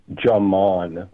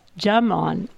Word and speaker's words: Jaman: The last name is pronounced Jaman with a J. – Oh. – So in Ukrainian, D and Z together form one letter, and they make a ja sound.